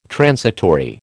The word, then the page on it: transitory: American English